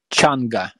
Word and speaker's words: Changa: This was your last experience with, how do you say it again? Chikanga? – Changa, yeah.